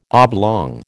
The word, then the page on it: oblong: American English